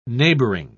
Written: neighboring

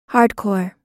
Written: hardcore

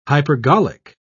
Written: hypergolic

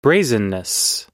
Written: brazenness

